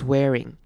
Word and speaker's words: swearing: 它是 ts 结尾的，所以这边我们要念成注音符号 z 的 ts 的声音，starts swearing。好，接下来看到 swearing 这个字，中间 ea 这边要念成长母音 a 的声音，swearing。